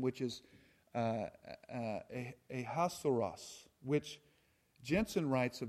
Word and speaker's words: Ahasuerus: And in the Hebrew, it is pronounced Achashverosh, and in English, Ahasuerus. So they say that it comes from the Hebrew, trying to say it in the Hebrew, and that's where it comes from.